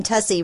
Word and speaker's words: Tussie: I've gotten really good at telling people my last name, Tussie, rhymes with fussy, and they remember that.